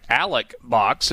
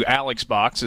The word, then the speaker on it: Alec Box: And you got the name right, Alec Box, as opposed to Alex Box, is how so many people pronounce it.